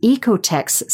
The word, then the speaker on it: OEKO-TEX: Or look for the Ecotex standard for green manufacturing. That's spelled OEKO-TEX.